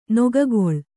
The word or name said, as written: ನಗ್ಗಲು